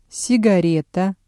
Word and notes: сигарета: Russian

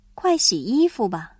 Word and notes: 洗衣服: xǐyīfu

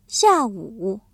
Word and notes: 下午: xiàwǔ

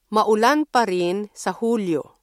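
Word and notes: Hulyo: Filipino

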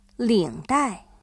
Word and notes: 领带: lǐngdài

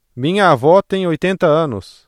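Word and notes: avó: Brazilian Portuguese

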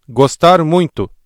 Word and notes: gostar muito: ブラジルポルトガル語

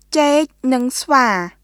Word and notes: ចេកនិងស្វា: チェーク・ヌン・スヴァー; ceːk nɯŋ svaː

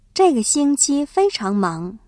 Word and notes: 这个星期: zhèigexīngqī

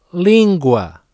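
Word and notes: língua: European Portuguese

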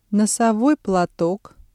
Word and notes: носовой платок: Russian

